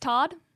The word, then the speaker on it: Todd: My Game Circle ID is Todd, T-O-D-D. Katie, what's yours? – Yours is just Todd. – Mine is Todd. I got T-O-D-D. – You got T-O-D-D. – T-O-D. You can't even say my name. – Todd. – Spell it. – T-O-D-D. – T-O-D-D.